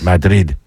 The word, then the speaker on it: Madrid: Madrid.